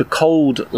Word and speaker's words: Cauld: of particular note, he's known as the Cold Lad, although it's spelled C-A-U-L-D, which I'm guessing is pronounced cold,